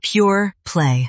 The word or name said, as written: pureplay